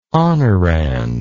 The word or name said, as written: honorand